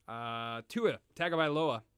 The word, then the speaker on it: Tua Tagovailoa: Tua Tagovailoa, if that is how you say it. Pretty sure it is.